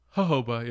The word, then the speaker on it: jojoba: Jojoba? – Jojoba, yeah.